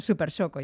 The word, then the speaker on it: Super Soco: Bueno, la siguiente tiene un nombre un poquito más común de pronunciar, Supercoco TC Max, que está un poquito Super Soco, ya me parecía a mí que la estaba pronunciando bien, pero se escribe Soco, Super Soco TC Max, que está un pelín por debajo en precio del anterior, ¿no?